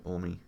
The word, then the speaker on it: Omi: Yeah, did I say that right? – Yeah, Omi.